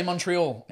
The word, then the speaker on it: Montreal: How do you say Montreal? – Montreal. – Without the T.